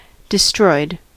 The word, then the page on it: destroyed: /dɪ.ˈstrɔɪd/